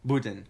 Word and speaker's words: Budin: Your last name, Budin. And I pronounced that correctly, correct? – Budin. – Budin.